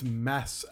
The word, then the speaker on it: Mads: I think it's Mass, actually. – Pardon me? – I've been told the D is silent.